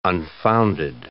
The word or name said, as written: unfounded